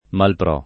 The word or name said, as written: mal pro